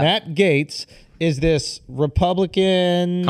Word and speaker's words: Gaetz: How do you pronounce his shit? – I think Gaetz. Gaetz. – Yeah, Matt.